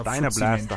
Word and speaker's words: Dyna Blast: Dyna, dein Dyna Blast. Ich kann es nicht aussprechen. Deine Deiner, englischer Deiner Blast heißt es.